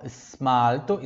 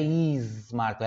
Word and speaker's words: smalto: Perceba, aqui ó, smalto, esse S aqui é só, o só o sopro do S mesmo, ó, smalto, tá? Não tem ali, você não vai pronunciar um i aqui, tá? Esmalto, não, não é esmalto, é smalto.